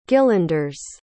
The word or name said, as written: gillanders